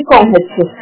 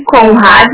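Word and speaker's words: Conradi: O teu Conrad é mudo ou tem o I no final? – O meu tem o I no final e daí é Monique Prim Conrad Conrad.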